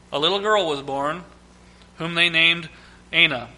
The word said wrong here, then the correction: Aina: I can't say it right. Ina. There we go. Ina. A-I-N-A. Ina.